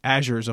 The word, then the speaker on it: Azure: It is not competing with the Amazons or the Azures of the world. And yes, I'm finally saying Azure instead of Azure. So if you've been cringing every time I pronounce it, you win.